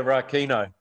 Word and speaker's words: Rakino: Incredibly brave girl survives being impaled on a branch on Rakino Island. Is that pronounced correctly? – Yeah, Rakino.